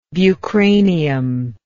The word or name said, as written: bucranium